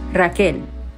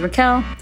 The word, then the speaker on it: Rachel: And so my name stayed spelled R-A-C-H-E-L, although it was always pronounced Raquel or Raquel. So flash forward, I'm 17. I'm at this point exhausted of explaining myself and having to explain the spelling of my name versus the pronunciation of my name.